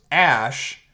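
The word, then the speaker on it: Æ: The AE in Scandinavian is pronounced Ash.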